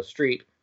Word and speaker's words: Street: Like when they ask me my last name, I go Street, like you drive on.